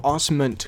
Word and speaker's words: Osment: Also, is it Haley Joel Osment or Haley Joel Osment? Because I feel like I pronounced it. I don't know who this is. – He's the... – Oh, is this the... – He's the child star who gets beat up in a bathroom. – Mix. I feel like it's with an M. – Haley Joel Osment. Oh, I've been saying that wrong my whole life.